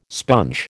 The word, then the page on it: sponge: American English